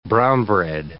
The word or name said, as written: brown bread